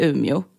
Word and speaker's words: Umeå: Men har man någonsin hört någon från Luleå, Piteå eller Umeå säga de här städerna som jag precis sa med det uttalet? Nej. Alla från Luleå, Piteå och Umeå säger Ume. Lule. Pite.